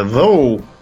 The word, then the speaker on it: though: а как бы, допустим, в слове though GH на конце не произносится никак.